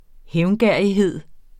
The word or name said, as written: hævngerrighed